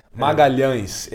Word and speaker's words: Magalhães: Is Vinny Magalhães, or I think I butchered the last name, sorry. – Magalhães. It's a very nasal sound.